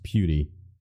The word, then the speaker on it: PewDiePie: Yes, but it's pronounced Pewdie.